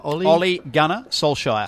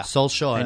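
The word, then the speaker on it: Ole Gunnar Solskjær: Ole Gunnar Solskjaer. – Solskjaer.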